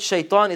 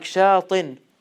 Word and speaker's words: shaitan: So, fa'lan, the word shaitan is on the same rhyme pattern. It's called nafsul wazn, right? Wazn, fa'lan. It rhymes with it. So, shaitan is not like shaitin.